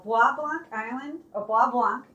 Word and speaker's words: Bois Blanc: So Bois Blanc is a French name, and locals had a hard time pronouncing the name because it's spelled B-O-I-S, then space, Blanc, B-L-A-N-C.